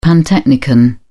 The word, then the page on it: pantechnicon: British English